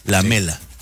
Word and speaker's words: Lamela: La sílaba tónica es importante. – Así, ¿verdad? – Lamela. Sí. Lamela. Sí.